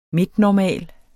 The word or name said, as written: midtnormal